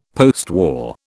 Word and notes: postwar: British English

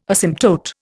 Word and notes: asymptote: Indian

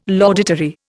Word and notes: laudatory: Indian

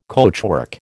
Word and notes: coachwork: American English

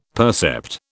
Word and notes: percept: British English

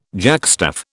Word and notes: jackstaff: British English

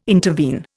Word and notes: intervene: Indian

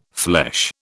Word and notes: flesh: British English

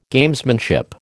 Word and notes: Gamesmanship: American English

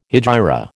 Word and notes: hegira: American English

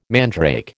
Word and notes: mandrake: American English

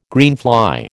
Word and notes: greenfly: American English

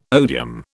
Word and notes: odium: British English

